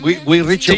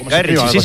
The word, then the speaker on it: Guy Ritchie: Lo he dicho bien, Guy Ritchie. – Sí, Guy Ritchie, sí.